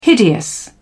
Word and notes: hideous: British English

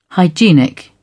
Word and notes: hygienic: British English

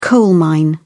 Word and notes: coal-mine: British English

